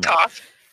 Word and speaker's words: Toph: Toph.